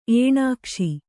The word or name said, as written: ಏಣಾಕ್ಷಿ